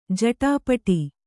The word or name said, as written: ಜಟಪಟ